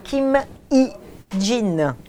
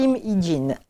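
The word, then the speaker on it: Kim Hye-jin: c'est chez Gallimard et je dirais qu'elle s'appelle Kim Hee-jin. – D'accord. – On va imaginer H-Y-E, ça se prononce I. D'accord. Kim Hee-jin.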